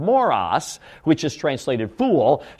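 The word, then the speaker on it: moros (μωρός): It's spelled this way in the Greek language, M-O-R-O-S, moros.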